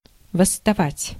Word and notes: восставать: [vəsːtɐˈvatʲ]

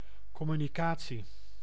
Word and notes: communication: France; [kɔ.my.ni.ka.sjɔ̃]

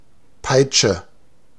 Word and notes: whip: [ʍɪp]; /wɪp/; American English

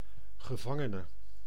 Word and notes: prisonnier: France; [pʁi.zɔ.nje]

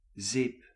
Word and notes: sapone: sa.ˈpo.ne

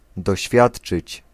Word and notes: subir: [sy.biʁ]; France

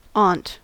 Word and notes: täti: /ˈtæ.ti/

